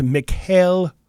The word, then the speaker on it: Mikhail: I believe it's pronounced Mikhail Hunt. – Correct. – Yeah. I believe that's how you say it. – Yeah. – Yeah, you don't want to get too messed up with that one. – Mikhail's the nice pronunciation. Yeah, yeah. We'll go with the Russian pronunciation.